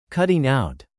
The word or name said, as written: cutting out